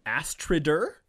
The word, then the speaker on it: Astridur: It's spelt Astridur. But it's pronounced Astrid.